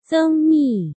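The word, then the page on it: Zeng Mi: Chinese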